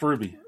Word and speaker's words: RWBY: Is the show called Ruby? Because I spelled it RWBY because that's what you sent me. – Right, yes, but it's pronounced Ruby.